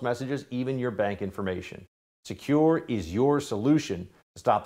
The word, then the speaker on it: Sekur: That's why you need a new cybersecurity company called Secure. It's spelled S-E-K-U-R.